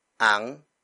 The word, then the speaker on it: ang: Bumukas ang pinto ng bahay ni Chris.